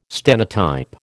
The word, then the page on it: stenotype: American English